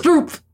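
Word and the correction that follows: Skroob: Scroop. It's the letters of Brooks mixed up.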